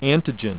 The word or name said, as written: antigen